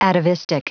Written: atavistic

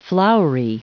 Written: flowery

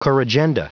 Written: corrigenda

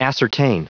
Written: ascertain

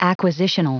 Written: acquisitional

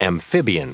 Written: amphibian

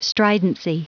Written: stridency

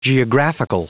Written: geographical

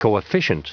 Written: coefficient

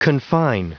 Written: confine

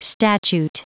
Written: statute

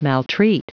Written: maltreat

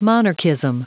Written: monarchism

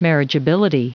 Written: marriageability

